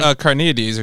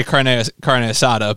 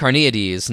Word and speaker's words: Carneades: Carneades or something. – Is it Carneades? – I do see it and say carne asada, but it's not. That's not it. Don't say that.